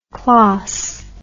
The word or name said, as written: klas